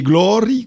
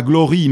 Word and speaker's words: Glory: l'abbé Glory, quasi, il faut prononcer non pas Glory, mais Glory à l'occitane.